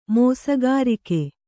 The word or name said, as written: ಮೋಸಗಾರಿಕೆ